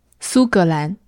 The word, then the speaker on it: 苏格兰: 苏格兰。